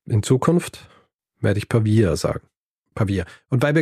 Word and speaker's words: Pavia: Also es ist nicht Pavia. Es heißt nicht Pavia. – Hm. – Es heißt Pavia.